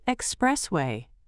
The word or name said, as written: expressway